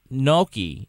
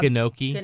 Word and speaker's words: gnocchi: You know, they certainly could have avoided a lot of that trouble, though, if instead of eating that gnocchi that was... – None of you can pronounce that. It's hilarious to me. – I call it gnocchi. – You're all wrong. – Gnocchi.